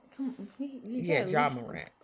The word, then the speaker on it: Ja Morant: Jay Morant. – Ja Morant? – Ja Morant.